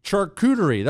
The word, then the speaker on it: charcuterie: Charcuterie. That's what I said. Don't interrupt when I'm saying stuff. Anyway, a big, what a fancy schmancy charcuterie board.